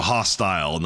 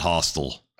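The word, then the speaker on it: Hostel: No, hostile, not hostile. – Oh, I did pronounce it hostile, but I meant hostile.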